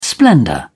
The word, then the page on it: splendour: British English